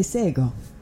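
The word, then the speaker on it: Shé:kon: When I first opened the show, you heard me say Sego. That is a Gonyageha word for hello, but it's not spelled like it sounds. It's spelled SHE:KON. The letter K sounds like a G.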